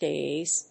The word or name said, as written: dais